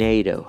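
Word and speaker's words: NATO: یکی دیگه از اینا هست ناتو، که باز این هم ما داریم به شکل خودمون تلفظش میکنیم، ناتو. در زبان انگلیسی این نیتو هستش، نیتو یا نیتو.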